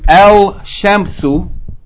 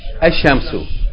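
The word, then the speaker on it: Ashamsu (الشمس): So instead of saying El Shamsu, we're going to say what? Ashamsu.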